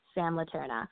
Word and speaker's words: Sam Leterna: which is just at Sam Laterna on both of those social media platforms. So I'm going to spell that for y'all, S-A-M-L-E-T-E-R-N-A. Sam Laterna.